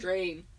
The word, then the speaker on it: Drane: D-R-A-N-E. – Oh. Okay. – But pronounced Drain? – Weird. – Drain.